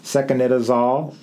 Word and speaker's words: secnidazole: Secnidazole and Solosec.